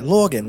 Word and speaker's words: Lorgan: It's spelled L-O-R-G-A-N. So like Morgan Bones.